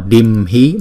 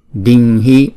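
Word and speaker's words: ādimhi: Chữ này chúng ta không đọc là dimhi mà đọc là ding. Đinh hi Soraho, Soraho Pachaya Đinh hi Bát, này là Pachaya, Pachá, Pachaya Đinh hi Ahang, Ahang Vandami Tăng Sada.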